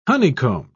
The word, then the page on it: honeycombed: British English